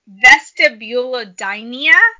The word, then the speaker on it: vestibulodynia: You can get something called, I'm not going to be able to pronounce this right, vestibulodynia. – Okay. – I can spell it for you. V-E-S-T-I-B-U-L-O-D-Y-N-I-A. – Yeah, right. That pretty much sounds like it.